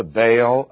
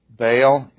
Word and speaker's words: Baal: If you are a purist and you want to pronounce everything correctly, then the word is Baal rather than Baal.